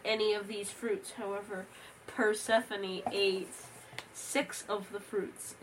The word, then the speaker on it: Persephone: I heard that Persephone, as you call her... – that's how you say it. – That's how you called her. – Because it looks like it says phone at the end.